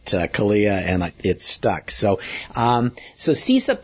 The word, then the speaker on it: CISA: CISA, yeah.